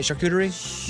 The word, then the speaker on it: charcuterie: Charcuterie? – Yeah, charcuterie.